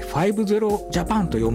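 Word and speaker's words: 00000JAPAN: 05 つとジャパンと書いて 50 ジャパンと読むんですけども、面倒な設定やパスワードがいらない Wi-Fi なので、誰でも簡単に使えるようになります。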